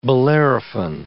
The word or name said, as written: Bellerophon